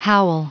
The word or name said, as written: howl